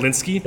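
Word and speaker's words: Hilinski: I hope I'm pronouncing his last name correctly. Helsinki. Sorry. – Helsinki. – Helsinki, yeah.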